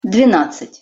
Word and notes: dvenadcat-: Russian

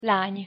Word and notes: lany: Hungarian

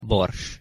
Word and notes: bors: Hungarian

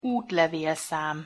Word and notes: utlevelszam: Hungarian

